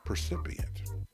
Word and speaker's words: percipient: You know, she would be the first woman sellout Mount Rushmore precipitant. Did I say that right? Precipient. I said precipitant. Percipient.